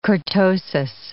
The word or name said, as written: kurtosis